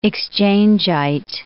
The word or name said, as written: Exchangite